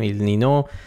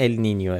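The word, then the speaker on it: El Niño: ولی تلفظ درست اگه میخواین ال نینیو، ولی همه جا تو فارسی به چرخید کسی چیکی نمیشه ال نینیو، همه جا نوشتن ال نینو.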